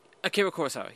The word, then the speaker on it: Akira Kurosawa: What is that? Am I saying that right? – Akira Kurosawa, yeah.